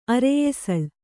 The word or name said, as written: ಅರೆಯೆಸಳ್